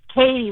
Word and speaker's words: Katie: No, it's Katie, like your coworker.